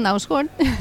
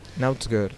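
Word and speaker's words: Knausgård: aquesta, aquest cercle petit que hi ha sobre la uh -huh. és mhm. una O petita. – Nausgard. – Que eh, avisa el lector o, que s'ha de pronunciar O.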